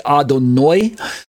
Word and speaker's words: Adonai: On the right side of the screen, we have Adonai in English spelled as A-D-O-N-A-I with a capital A. And the explanation is that it's pronounced as Adon plus I, E-Y-E. And so it comes out as Adonai. Some Orthodox Jews will say Adonoi. And some people say Adonai, A-D-O-N-A-Y.